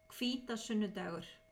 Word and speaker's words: Hvítasunnudagur: The 31st, we're just going to add in the proper way to say Whit Sunday. – Hvítasunnudagur.